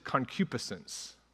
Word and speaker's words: concupiscence: The word is concupiscence.